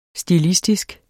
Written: stilistisk

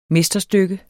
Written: mesterstykke

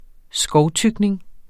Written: skovtykning

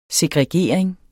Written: segregering

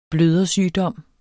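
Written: BLØDERSYGDOM

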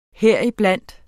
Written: HERIBLANDT

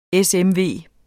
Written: SMV

